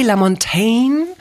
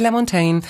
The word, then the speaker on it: LaMontagne: Tja, heute mit Klaus-Maria Brandauer und jetzt erstmal mit Ray LaMontagne, der wirklich so ausgesprochen wird, obwohl er so schön geschrieben wird, LaMontagne, Ray LaMontagne, und der Song heißt Trouble.